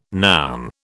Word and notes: noun: British English

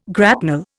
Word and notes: grapnel: Indian

